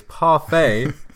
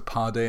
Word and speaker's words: parfait: And you say, everything is parfait apart from your pronunciation. – Oh, I should have said it's a Pardait in my mouth.